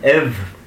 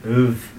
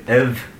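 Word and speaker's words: ubh: If you were to give somebody an egg or boiled egg or an egg, you'd say an iv. Now, in the Ulster Irish, we would say an iv. Some people would pronounce it uv, but we say iv.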